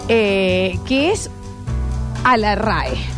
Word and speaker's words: RAE: En vez de ir, es la RAE, lo dije bien ahora, sí.